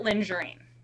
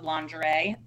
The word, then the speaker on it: lingerie: The point was lingerie, but he pronounced it lingerine. So would that be a red flag or a deal breaker? And he had no idea when you said lingerie that that was the actual pronunciation. It was not a mess up. He thought it was pronounced lingerine.